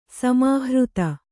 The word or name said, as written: ಸಮಾಹೃತಿ